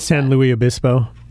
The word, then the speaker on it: San Luis Obispo: I can't pronounce San Luis Obispo.